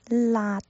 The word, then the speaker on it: 辣: 下一个是辣椒的辣，l a 辣，加上声调是 la l a 辣，发音结束的时候，舌尖啊，记住了，这个是舌尖，不是舌根，舌尖要轻抵上颚，把气流截住。